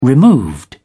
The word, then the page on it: removed: British English